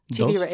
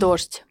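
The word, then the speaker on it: Dozd (Дождь): It was basically a translation summary of something that ran on the independent news, Russian news network, Dozd, am I saying it right? Dozd. It's TV Rain. – Mm-hmm. Wait, okay. Say that one more time. – Dozd.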